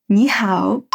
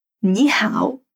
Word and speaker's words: 你好: Ni hao. Ni hao.